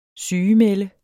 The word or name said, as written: sygemelde